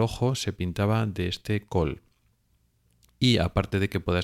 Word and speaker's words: kohl: la gente se pintaba con un pigmento llamado col, que se escribe K-O-H-L,